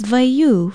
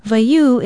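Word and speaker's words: waiū: Aloha, I'm Leilani Poliahu of Hawaii Public Radio, and our Hawaiian word of the day is vaiu for milk. Vaiu is spelled W-A-I-U with the macron or stress mark, ikahako over the U.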